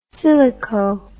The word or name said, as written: silico